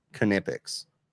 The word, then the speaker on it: Knipex: So about a week or so ago, I have you guys use, how do you pronounce it? Knipex? Knipex? – It actually is Knipex.